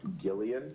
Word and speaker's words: Gillian: her name is pronounced Gillian Jacobs.